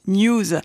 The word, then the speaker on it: N3W5: Elle s'écrit N3W5, mais se prononce News.